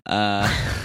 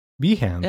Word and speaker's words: Behance: It's the Gruber pronunciation. Behance. – Behance. – Yeah, like enhance.